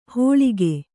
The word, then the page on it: ಹೋಳಿಗೆ: Kannada